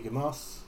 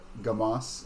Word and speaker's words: Gamas: Looking at our map, the city appears to be Gamos. – Yes. – Am I saying it right? – Yeah. – We're in Dumbass? – No, it is Gamos. – Oh, G-A-M-A-S.